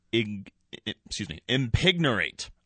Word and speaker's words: impignorate: I'm going to try and pronounce it correctly. – Yeah. – Impignorate. – Ah. – Pretty good.